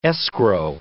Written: escrow

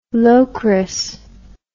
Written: lokris

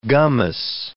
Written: gummous

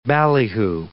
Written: ballyhoo

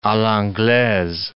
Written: a l'anglaise

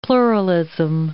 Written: pluralism

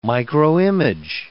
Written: microimage

